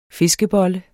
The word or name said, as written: FISKEBOLLE